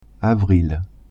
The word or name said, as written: avril